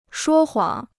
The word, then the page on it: 说谎: shuō huǎng